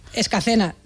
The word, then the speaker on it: Escacena: Nacida y crecida y vivida en Escalena del Campo, en Huelva. Escacena, mira que te lo he dicho mal varias veces, por esta, Escacena.